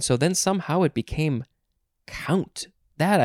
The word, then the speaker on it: compt: And there's more at the word count. Oh, interesting. I think I just made a connection. We had that weird word in yesterday's episode, C-O-M-P-T, which could be pronounced count.